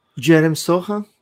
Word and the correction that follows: Sochan: Jeremy Sochan.